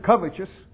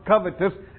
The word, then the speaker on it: covetous: You might pronounce it covetous. I was told that it's pronounced covetous, and that's what it really looks like.